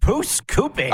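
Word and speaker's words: Pouce Coupe: Our good friend Jack Wilson showing up from QR Calgary, longtime Calgary broadcaster, host of Flames broadcast, Stampeders broadcast, and I never get your hometown properly pronounced. Pusakoopy? – Pusakoopy. – I was close.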